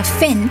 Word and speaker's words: Fionn: And then we've got a sibling duo from Vancouver called Finn. It's spelled F-I-O-N-N.